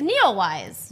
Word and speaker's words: Neowise: It's Neowise. – Neowise. – It's just Neowise. Comet Neowise.